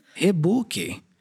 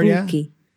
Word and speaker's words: Reebok: Hibuki. – Hibuki. – Hibuki. Hibuki. – He. Oh, Hibuki. – Yeah, he at the beginning. – He. Like hair? He? – Similar, Hibuki. Yeah.